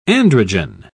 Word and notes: androgen: British English

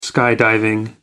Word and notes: skydiving: American English

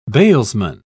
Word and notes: bailsman: British English

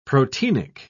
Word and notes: proteinic: British English

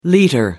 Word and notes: liter: American English

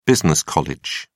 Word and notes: business college: British English

